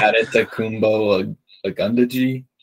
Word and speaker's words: Adetokunbo Ogundeji: Adetokunbo Ogundeji, something like that.